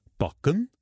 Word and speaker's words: Bokken: Cleo har en lille rød lædertaske, der hedder Bokken. Ja, det skal udtales på norsk, parentes bemærket, fra hendes diskotid i Italien.